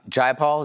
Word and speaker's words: Jayapal: Jayapal, is that how you say it? – Yep.